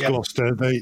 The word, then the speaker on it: Gloucester: Yeah, the town was Gloucester.